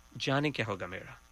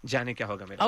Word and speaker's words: Jaane Kya Hoga Mera: But first, this is something called, I hope I'm saying this right, is it Hoga Mera? Did I say that correctly? – Jaane Kya Hoga Mera. – Ah, there we go. – Jaane Kya Hoga Mera.